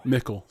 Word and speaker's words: Mickle: Pickle was his last name? – Mickle, sorry. – Spelled like pickle, but with an M.